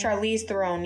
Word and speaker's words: Charlize Theron: And so Charlize Theron, is that how you say her name? – Charlize Theron, yes.